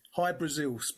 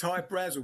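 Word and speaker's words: Hy-Brasil: There's High Brazil, people pronounce it High Brazil, and it's Brazil, okay. – yeah, yeah. But it's High Brazel because that's how the Irish would pronounce it, because it's an it's an island that's off the southwest coast of Ireland.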